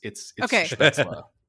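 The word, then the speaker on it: Spätzle: I think it's Spetzla. And then a bunch of people tweeted at me that it's Spetzla. But I'm sure I'm still mispronouncing it, I'm sure.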